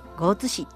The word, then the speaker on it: 江津市: ちなみに江津市の漢字は、松江の江に大津の津と書いて江津市と読みます。